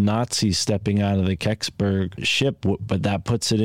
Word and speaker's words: Kecksburg: If anyone looks it up on Wikipedia, it's spelled K-E-C-K-S-B-U-R-G. Keksberg incident, and that's Keksberg, Pennsylvania.